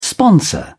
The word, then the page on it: sponsor: British English